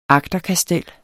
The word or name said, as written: agterkastel